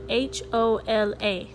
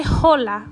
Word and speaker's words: hola: In Spanish, the H is silent. Okay, so the word hola is actually spelled H O L A so we don't say hola, we say hola.